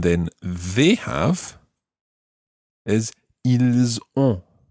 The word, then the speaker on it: ont: And that's spelled O-N-T. Ont. – Ont. – Ils ont. – Ils ont. – And make sure that you sort of make that an on sound as opposed to an en sound.